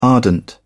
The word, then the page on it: ardent: British English